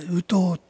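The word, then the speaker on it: 烏頭: つまりカラスの頭って、ウトウって発音は同じなんです。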